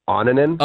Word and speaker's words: Annunen: Well, first of all, his name is pronounced Annunen. – Okay. Well, Nick Kieser? Because Nick Kieser, I said Annunen, and he said Annunen. – It's Annunen, but some say Annunen. So you have you've got options.